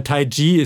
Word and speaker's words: Taiji: Aber Tai Chi ist die richtige Aussprache.